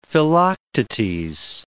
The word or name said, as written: Philoctetes